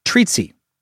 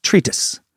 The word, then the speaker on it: treatise: In multiple episodes, I've heard you say treatsy. I think you're trying to correctly say treatise. It's pronounced treatise.